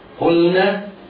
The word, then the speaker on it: قلنا: قلنا هنا ملاحظة هامة يخطئ فيها كثير من المبتدئين، وهي أنه قد يسبق لسانه فينطق بإدغام اللام في النون، في مثل قلنا، جعلنا، أنزلنا وما شابه ذلك. وهنا ينبه على المتعلم أن يعتني بإظهار اللام حتى لا يسبق لسانه وينطقها مدغما هكذا: وإذ قلنا للملائكة.